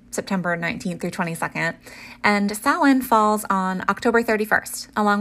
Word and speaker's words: Samhain: Samhain, which, you know, is pronounced Samhain, but is spelled like Samhain or S-A-M-H-A-I-N, but it is actually a Gaelic word.